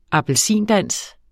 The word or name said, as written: appelsindans